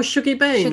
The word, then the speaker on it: Shuggie Bain: Oh, Shuggie Bain.